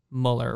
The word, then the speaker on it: Muller: And for those who don't know who this disembodied voice is, his name is Harrison Muller, right? – Yep.